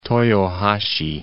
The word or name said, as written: Toyohashi